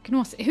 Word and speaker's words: Gnosis: Gnosis, hur uttalas det? I mitt huvud låter det... – Gnosis.